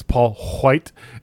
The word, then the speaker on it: White: Scant few details have come out other than it's Paul White and I'm pronouncing that H every time.